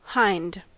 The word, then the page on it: hind: American English